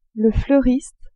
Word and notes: fleuriste: female

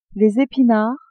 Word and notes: epinards: female

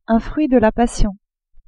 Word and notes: fruit de la passion: French

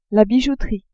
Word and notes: bijouterie: female

